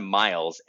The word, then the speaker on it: Myles: I'm Miles at uof.digital. If anybody wants to send me an email, Myles is how my first name is spelled.